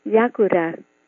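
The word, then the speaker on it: Liakoura: In Greek, it is pronounced Yakoura.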